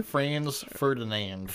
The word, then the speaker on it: Ferdinand: Franz Ferdinand. – Ferdinand's. It's just the way I have it. There's no S at the end of it.